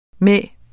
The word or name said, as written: med